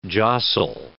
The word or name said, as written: jostle